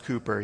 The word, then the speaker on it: Cowper: William Cowper, his name is spelled Cowper, but it's pronounced Cooper.